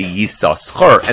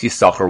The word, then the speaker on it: Yissachar: Ben Naphtali said to do so with both Sinin, in other words, say Yissachar, and that's how you should pronounce it throughout the entire Torah. Ben Asher says you should only pronounce one of them, and that obviously is what we do.